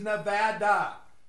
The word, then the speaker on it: Nevada: It is Nevada. That's how you pronounce it.